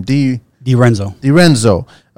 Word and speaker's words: di Renzo: Di Renzo.